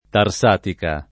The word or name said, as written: Tarsatica